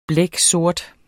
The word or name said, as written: blæksort